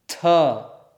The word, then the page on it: th: sanskrit